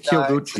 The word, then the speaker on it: Kiyoguchi: Yeah, you say it real quick, the Kyo. – I think it's Kiyoguchi. – The same character, you know, the city Kyoto? – Right.